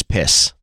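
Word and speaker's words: PiS: But it's better if you pronounce them by their English acronym, which is PISS.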